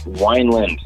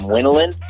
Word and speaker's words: Wineland: People used to pronounce my name Winland, mainly at Applebee's and when they're calling for my party. So good to hear you say it right.